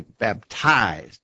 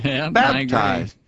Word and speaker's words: baptized: Chris said, he said he does think a number of older preachers used to say baptized. You must be baptized. He said the first syllable was short and the emphasis was on the second syllable. You need to be baptized. I don't say it that way. – Well, I say baptized. I think if I'm hearing, I'm not good at hearing those accents, Anthony, but I think if I'm hearing it right, I say it with the accent on the first syllable, baptized.